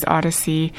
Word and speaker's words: Oddisee: That was Washington, D.C.'s Odyssey. – Odyssey is spelled O-D-D-I-S-E-E.